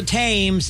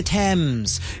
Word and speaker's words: Thames: I say, I don't mean to be impertinent and correct you, but it's not the Thames, it's the Thames. Even though it's spelled T-H-A-M-E-S, it's Thames. It makes no sense. It's spelled Thames, but we pronounce it Thames.